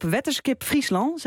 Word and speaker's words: Wetterskip Friesland: En wij zoomen vandaag in op Wetterskip Friesland, zeg ik dat goed? – Ja, want dat zegt helemaal goed.